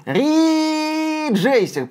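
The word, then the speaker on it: Ridge Racer: Ридж Рейсер, правильно говорить.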